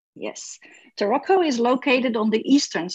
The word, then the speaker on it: Taroko: So let's jump right into the national park, into Taroko. Am I saying that right? Taroko? – Yeah, Taroko.